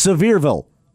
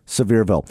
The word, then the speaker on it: Sevierville: Yeah, there is some discussion about how you pronounce that, even among people who live in eastern Tennessee, but it seems like the consensus is it's Sevierville, like severe weather, Sevierville.